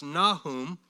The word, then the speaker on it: Nahum: Now, the next one, excuse me, prophet number seven is Nahum, or Nahum, pronounced Nahum, ministered in Nineveh about 650 or so BC.